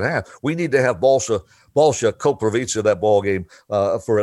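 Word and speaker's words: Balša: But it's Balsa. He goes Balsha, S-H-A, Balsha. It's spelled S-A.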